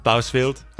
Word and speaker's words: Bousfield: Bousfield. – Okay. Easy enough. – Orgilbold. – Okay. Those are, okay, exactly how you read them.